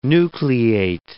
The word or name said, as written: nucleate